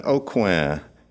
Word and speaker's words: Aucoin: My name is Kevin Aucoin, and it is spelled A-U-C-O-I-N, for those of you who aren't from South Louisiana.